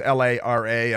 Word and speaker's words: Lara: It's L A R A, and and pronounced she's South African uh, originally, and and LARA Lara Logan, she goes by, and she has been a very serious reporter.